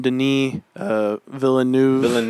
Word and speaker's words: Villeneuve: But yeah, if you know, didn't guess by now, we're covering Dune this episode because the new Dune came out from Denis Villeneuve.